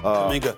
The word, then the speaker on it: Kuminga: Kuminga.